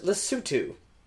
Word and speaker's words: Lesotho: The country Lesotho is pronounced Lesotho.